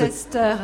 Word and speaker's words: Worcester: Worcester, c'est quand même pas sorcier.